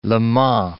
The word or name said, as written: Le Mans